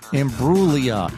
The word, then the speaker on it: Imbruglia: That's where the G is silent in her last name, right? Imbruglia.